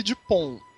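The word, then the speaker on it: Dupond: Não, é Dupont e Dupont. Eles falam absolutamente iguais em francês, é de propósito. O francês não pronuncia a última consoante.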